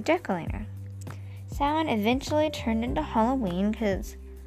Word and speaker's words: Samhain: It's spelled S-A-M-H-A-I-N, so it's like Samhain, but it's pronounced Samhain. Samhain was the first day of November, and the Celts would celebrate the start of winter.